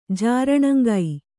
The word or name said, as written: ಜೀರ್ಣಾಂಗ